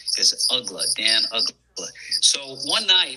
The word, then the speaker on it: Uggla: When the Marlins acquired through the Rule 5 draft, it was amazing how they got this guy, Dan Uggla.